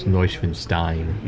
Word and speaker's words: Neuschwanstein: And I just realized I said that wrong. It's Neuschwanstein.